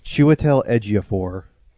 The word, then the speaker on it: Chiwetel Ejiofor: It's Chiwetel Ejiofor.